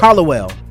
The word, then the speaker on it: Hollowell: Am I saying that right? Howe? – Yep. – Hollowell.